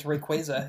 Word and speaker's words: Rayquaza: If it's Rayquaza, yes. – Is that how you say it? I always say like Rayquaza. – On the anime, they say Quasar, like a Quasar.